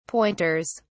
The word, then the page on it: pointers: British English